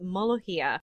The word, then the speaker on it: molokhia: This is called molochia and I apologize if I get it wrong because it's spelled a lot of different ways. The most common one in this article is M O L O K H I A, but pronounced molochia.